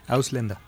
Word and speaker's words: Ausländer: Ausländer.